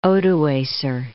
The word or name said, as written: Odoacer